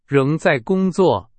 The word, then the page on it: 仍: Chinese